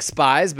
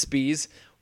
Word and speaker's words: Spies: It looks like Spies, but it's actually pronounced Spies, was the guy who originally was sent this rewrite.